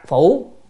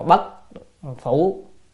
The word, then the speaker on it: phủ: tức là hỏi cư sĩ rằng nhữ kiến tặc phủ. Chữ này không phải đọc là bất phủ.